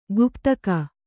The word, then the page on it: ಗುಪ್ತಕ: guptaka